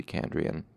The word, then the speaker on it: Chandrian: Now that's um, that's sort of a weird word, but it's it's spelled C H A N D R I A N, Chandrian. So that's how I'm going to pronounce it. It might be Chandrian. Um, or, or possibly Candrian, but I'm going to say Chandrian.